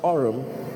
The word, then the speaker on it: arum: Orum is actually spelled A-R-U-M, but it is pronounced orum.